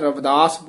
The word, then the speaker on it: रविदास: राग गौड़ी रविदास जी के पदे आम इथे भी रविदास बिहारी नाल उच्चारण कर जांदे ने, लिखिया सिहारी नाल है, रविदास बोलना है।